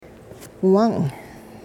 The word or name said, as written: wung